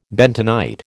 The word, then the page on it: bentonite: American English